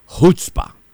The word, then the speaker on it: chutzpah: A palavra é chutzpah. Se escreve C-H-U-T-Z-P-A-H.